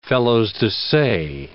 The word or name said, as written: felos-de-se